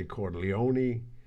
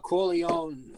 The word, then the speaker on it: Corleone: The Corleone, without the pronunciation of the E, is the bastardized American version.